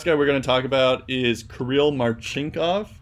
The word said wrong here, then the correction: Marchenko: Marchenko, sorry, another hard to pronounce last name here.